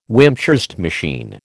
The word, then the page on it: Wimshurst machine: American English